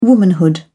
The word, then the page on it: womanhood: British English